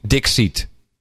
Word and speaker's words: Dikshit: D-I-K-S-H-I-T. And the guy, yes, and the guy actually, well, he's supposed to be Dixit, because that's how Dixit. You pronounce an Indian name.